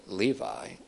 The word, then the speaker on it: Levy: David Levy, also, I guess you could pronounce it Levi, L-E-V-Y, makes the following observation in his very excellent and helpful book on the tabernacle called The Tabernacle, Shadows of the Messiah.